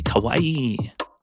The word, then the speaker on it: Kawaii: I should pronounce it properly. Kawaii.